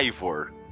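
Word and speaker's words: Eivor: Ó, eles têm um tópico aqui ensinando a pronunciar o nome do personagem. É Ivor. Ivor.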